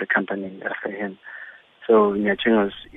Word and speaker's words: Nyachengos: Am I saying it right? The name Nyachengos, is that right, Auntie? – Nyachengos.